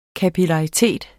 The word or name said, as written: kapillaritet